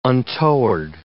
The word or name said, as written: untoward